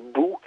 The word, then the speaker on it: boha: Oui, cet enregistrement est un incunable, comme on dit, puisque c'est le seul dont on dispose d'un joueur de cornemuse landaise qu'on appelle Bou, là, ça s'écrit B-O-H-A.